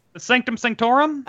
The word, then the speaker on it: Sanctum Sanctorum: And I thought that was a really good So then Hulk crashes in Doctor Strange's Sanctum Sanctorum. Wait, how do I say that? – Sanctum Sanctorum? – Oh, was I right? Okay. – Yeah, I think so, yeah.